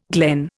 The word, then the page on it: glen: Indian